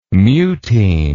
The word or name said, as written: mutein